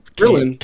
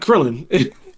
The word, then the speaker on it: Kirlian: I don't even know if I'm saying it right. It's Kirlian. – K-I-R-L-I-A-N. – So like Kirlian. – Yeah, but it's curl, like Kirlian.